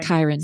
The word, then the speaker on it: Chiron: Although I think my favorite part in the book is in the middle part where Achilles is being trained by, is it Chiron? – Chiron. – C-H-I-R-O-N.